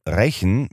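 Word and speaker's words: Rächen: Deshalb schreibt man Rechen mit Ä, weil es mit Rache zusammenhängt, auch wenn man es Rechen mit E ausspricht.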